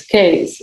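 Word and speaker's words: Kaes: No, it's Case. It's like a case for your body.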